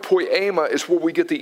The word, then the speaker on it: poiema: The way you spell that word is P-O-I-E-M-A, and it is pronounced poiema, poiema.